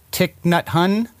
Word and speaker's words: Thich Nhat Hanh: Is it Thich Nhat Hanh? – Very good.